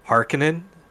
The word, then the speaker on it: Harkonnen: taken aback by in the movie when they would say Harkonnen instead of Harkonnen. – Yeah, the emphasis on the wrong syllable.